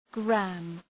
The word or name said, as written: gramme